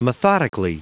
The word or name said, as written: methodically